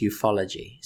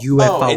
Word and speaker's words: ufology: Ufology, it says it's ufology, so it's not UFOology.